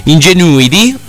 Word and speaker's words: Ingenuity: Ingenuity eh, che si chiama così, questa è la sua pronuncia, è inutile che dite cose diverse,